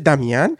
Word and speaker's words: Damien: In this week's episode, we are going to discuss La La Land, the Damien Chazelle 2016 film. Wait, Damien? Is that right? – Damien. – Okay. For a second, I'm like, wait a second. Is it Damien?